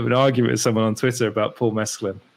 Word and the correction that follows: Mescal: Paul Mescal, I get his name wrong all the time.